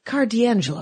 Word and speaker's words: Carr D'Angelo: Sitting in my living room, the guy that is the controller of my pull list, Carr D'Angelo, is that correct?